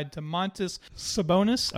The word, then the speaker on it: Domantas Sabonis: The skills competition was won by Domantas Sabonis.